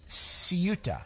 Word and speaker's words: Ceuta: Ceuta is how it's pronounced.